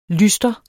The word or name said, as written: lystre